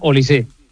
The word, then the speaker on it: Olise: No, es francés. Es no francés. Lleva acento, pero se pronuncia Olise.